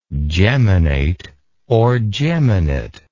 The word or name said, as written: geminately